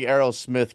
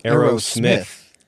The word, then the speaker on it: Aerosmith: Well, I was a big Aerosmith kid growing up. – I've never heard Aerosmith pronounced that way. – Yeah, he really put emphasis on the Smith. – Aerosmith.